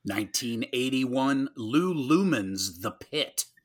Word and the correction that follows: Lehman: Is that how you pronounce his last name? It's not Lou Lehman? – Probably Lehman. I read it wrong.